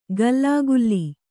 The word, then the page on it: ಗಲ್ಲಾಗುಲ್ಲಿ: gallāgulli